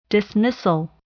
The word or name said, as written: dismissal